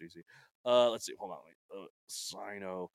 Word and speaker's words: Sinosauropteryx: Sinosauropteryx. – Sinosauropteryx.